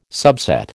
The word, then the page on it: subset: American English